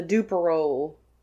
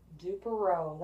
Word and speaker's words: Duperrault: So, we are spotlighting the Duperol family. – Duperol. That's a name, ain't it? – You want to know how it's spelled? – Yes, I do. – It's not spelled how it's pronounced.